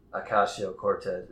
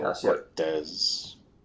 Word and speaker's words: Ocasio-Cortez: Yeah, but what I have to say to that is, Ocasio-Cortez is, did I say it right that time? I know I fucked it up last time. – I believe that is correct. – Ocasio, Ocasio-Cortez.